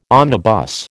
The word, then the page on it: omnibus: American English